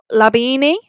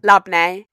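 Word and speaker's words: labneh: Okay, so for you we've got labini. Is that how you say it? – Labne.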